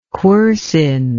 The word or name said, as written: quercine